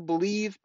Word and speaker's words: Bleav: It's on the Believe Podcasting Network. It's with Lions legendary safety, Benny Blades. You got to check it out. It's called B-L-E-A-V. They pronounce that Believe in Lions on the Believe Podcasting Network.